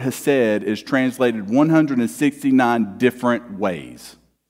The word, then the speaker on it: hesed: And this word here that we find in Ruth, this word for kindness in verse 20, is a Hebrew word called hesed. Sometimes it's pronounced chesed, right?